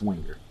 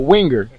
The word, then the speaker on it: Winger: I said your last name wrong? – It's fine. It's Winger. – Winger? – Yeah. – Say it again, because I said it wrong that time, too. – Winger. – Winger. – One R at the end. Mm. Not Wrangler like the jeans.